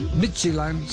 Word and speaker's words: Midtjylland: Midtjylland, se pronuncia como esa de esa de Midtjylland.